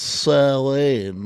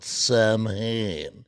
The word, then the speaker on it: Samhain: Let's pronounce Samhain, not Samhain.